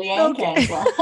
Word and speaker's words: Leanne Kanzler: Us Aussies, we don't pronounce the R like that, but that's cool. Leanne Kanzler.